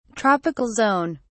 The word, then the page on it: tropical zone: British English